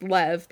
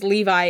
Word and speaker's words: Lev: Now, I'm assuming his name is pronounced Lev, because it's spelled L-E-V. – Yeah. – And I kept reading it as Levi in my head, so it's Lev.